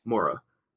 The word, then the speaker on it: Moira: So it's, how do you say it? Mora? Moira? – Mora. It's Irish.